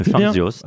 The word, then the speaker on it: Chantzios: Chantios.